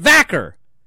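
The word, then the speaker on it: Vaquer: Remember when Stephanie Vacher got called up and they were like, it's Stephanie Vacher. That's how she pronounces it, Vacher. They got mad at us because that's how she pronounced it.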